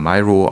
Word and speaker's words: Miro: sondern auch mit Systemen wie Miro oder Miro ausgesprochen oder Conceptboard,